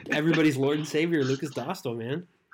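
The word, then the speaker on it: Dostál: I have a Czech friend on my roller hockey team who I asked him for pronunciation advice, and apparently it's Lucas Dostal. – Dostal.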